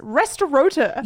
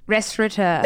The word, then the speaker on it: restaurateur: It should be restaurateur, which is how I've always pronounced it, but it's restaurateur. – Yeah, and I've only realized, I reckon, in the last 6 to 12 months that there's no N in that word, but I've still continued to pronounce it the way I believe it should be pronounced.